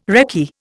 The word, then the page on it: recce: Indian